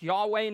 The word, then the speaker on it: Yahweh: Tom Wright points out that the ancient Israelites, when they came to those consonants, Y, H-W-H, that we pronounce Yahweh now, would say instead Adonai.